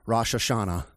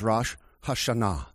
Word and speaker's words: Rosh Hashanah: Now, most Americans pronounce it Rosh Hashanah, but in the Hebrew, it is Rosh Hashanah.